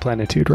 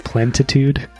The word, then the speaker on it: plenitude: You almost said plenitude right. – Did I say it wrong? – Yeah. – Snap. Well, that's just real life for you, isn't it? – It's okay. I think you said plentitude.